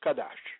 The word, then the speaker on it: chadash: is chadash, pronounced kadash, meaning fresh or new.